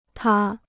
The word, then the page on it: 塌: tā